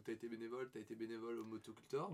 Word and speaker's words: Motocultor: Motocultor.